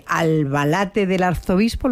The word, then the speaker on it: Albalate del Arzobispo: Pero bueno, llegas a este pueblecito de Albalate del Arzobispo, ¿lo he dicho bien? – Sí.